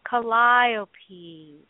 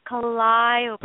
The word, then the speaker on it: Calliope: It just rolls off the tongue. Calliope. – Say it with me. Calliope. – Calliope.